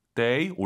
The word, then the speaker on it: 得: 데 올라가면 안 됩니다. 뒤에가 일성이잖아요. 반삼성이죠. 워먼 데이 추먼.